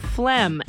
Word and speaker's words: phlegm: Phlegm. And it's not. What's weirder is to see it written because it's spelled P-H-L-E-G-M. It's like phlegm.